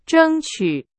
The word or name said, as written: 争取